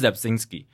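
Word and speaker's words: Rzepczynski: 这超难的，因为 R 不发音嘛，应该是 Zabzinski。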